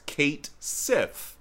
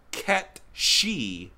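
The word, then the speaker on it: Cait Sith: It looks like in English it would be pronounced Kate Sith. I have heard that's completely wrong. I have heard it should be pronounced Ket She, because it's based on some sort of Nordic folklore or fairy tale.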